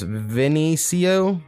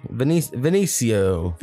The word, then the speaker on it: Vinniecio: And I'm going to say this artist's name is pronounced Vinicio. Vinicio. – Vinny, Vici. – V-I-N-N-I-E-C-I-O. Vinicio. That's how I'm going to say.